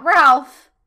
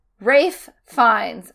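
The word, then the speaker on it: Ralph Fiennes: But he's played by, not Ralph, Rafe Fiennes. Rhymes with safe signs.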